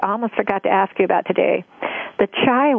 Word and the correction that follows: chia: one. – Oh, you mean the chia? – Oh, yeah. How do you pronounce it? – Yeah, chia.